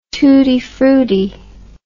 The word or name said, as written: tootie fruttie